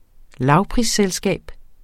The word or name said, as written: lavprisselskab